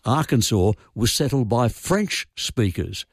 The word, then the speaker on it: Arkansas: So Kansas is pronounced Kansas, but the one next door, Arkansas, is pronounced Arkansas.